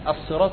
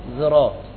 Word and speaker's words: الصراط: الصراط هي نفس الرسم واحد، مش مثلا الصراط ترسم بالزاي، لا، ده دي طريقة نطق الصاد، طريقة نطقها عند البعض اللي هو الإشمام، الصراط يعني ينطقها بهذا بهذه الطريقة بهذه الطريقة، أو يرققها الصراط. فبعض العرب ينطق الصاد بإيه؟ بالسين، صوت السين، يبقى الصراط صراط صراط ذراط، والرسمة واحدة هي الصاد أصلا، بس هذه طريقة نطق.